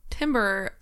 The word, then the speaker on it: timbre: I just want to make a quick call that not everyone may know Timbre is also a call to the music theory. – Absolutely. Timber. Uh, you- it's actually pronounced like timbre or something.